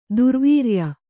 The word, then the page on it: ದುರ್ವೀರ್ಯ: durvīrya